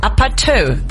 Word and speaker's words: APT: Ist koreanisch, steht für Apartment und heißt Apatö. Apatö. Apatö.